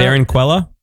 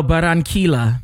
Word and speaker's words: Barranquilla: Oh, how do you say the city in Colombia? – You say this city in Colombia. – Barranquilla? – No, Barranquilla.